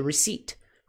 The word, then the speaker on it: receipt: Muito importante, pronúncia, não é receipt. Esse P não é pronunciado, é receipt, receipt, receipt, receipt, ok? O P é como se não existisse na pronúncia.